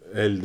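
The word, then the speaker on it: Elle: Yani daha doğrusu Emma Stone'dan almasını istiyorum e, En iyi kadın oyuncu Isabel Huppert El. Ee, El değil mi? Öyle okunuyor. Evet.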